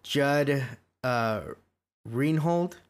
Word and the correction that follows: Judge Reinhold: is that how you say his name? – Judge Reinhold.